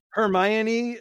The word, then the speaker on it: Hermione: How do you say her name? – Hermione. – Hermione as number three.